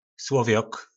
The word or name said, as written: slovjok